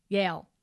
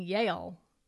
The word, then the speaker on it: Yale: I was told this weekend by our friend Kate, who is an alumna, yes, that I have been mispronouncing it all these years. I've been saying Yale. She's like, it's not two syllables.